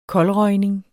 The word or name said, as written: koldrøgning